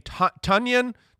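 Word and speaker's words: Tonyan: I mean, Tunyon. – Funyon. – Right. – I know. Right. Always remember, rhymes with Funyon.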